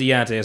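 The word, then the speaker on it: D'Unte: Started with a D, Dante, but it's spelled D apostrophe U N T E, mad extra. – That's Dante. – Yeah, that's how I looked at it. And the white guy was like Deontay. Like, he would just try to figure it out some kind of way. Yeah, but I think it's Deontay or something like that. That's exactly how you pronounce it.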